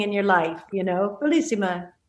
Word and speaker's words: Bellissima: So Bellissima, I'm saying that right? Yeah. Right? Yes. – Yes. Is Bellissima.